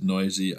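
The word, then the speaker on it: Neuse: Sheldon, I think it's Noisy. I think he pronounces it Noisy.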